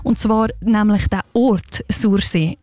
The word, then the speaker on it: Sursee: Einmal so aussprechen, wie ein Einheimischer oder ein Einheimische das würde. Und ich komme ja selber auch von dort und zeige es euch jetzt schnell mal vor. Sursee. Nicht anders, Sursee.